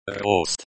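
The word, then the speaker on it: Rost (рост): Rost. Rost, the O in rost is pronounced like the O in hot, and the T is pronounced like the T in top.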